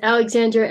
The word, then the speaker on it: Alexandra: My name is pronounced Alexandra Adlawan. Adlawan, it's Filipino.